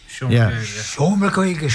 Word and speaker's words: Seomra Gaeilge: Seomra Gaeilge, is that how you pronounce it? – Seomra Gaeilge. Yeah.